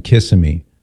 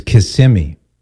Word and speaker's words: Kissimmee: I'll never go back to my wayward days of saying Kissimmee. It's Kissimmee. I've been adamantly corrected on that.